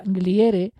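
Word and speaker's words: Glière: Alrededor de 1900 lo cambió a la pronunciación a la francesa Gliere, lo que dio origen a una especie de leyenda de su supuesta ascendencia francesa o belga, que es falsa.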